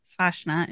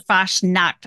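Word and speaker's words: Fasnacht: Fashna's the event, or Fashnacht, I guess is how you say it. Fashnacht. I learned that by watching Chip's video. Well, Brian's video for Chipmunk Travel Series, whatever. He released the Fashnacht video last week, I think, and talked to the organizer of the event. And she said it. And I was like, oh, we've all been saying it wrong.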